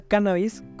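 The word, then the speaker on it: cánnabis: La palabra cannabis, con acento en la la primera A,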